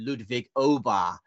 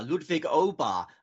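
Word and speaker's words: Åberg: because I discovered last week we've been getting our pronunciation wrong on Ludwig, because I saw him do a piece to camera last week where he said, "Hi, I'm Ludwig Aber."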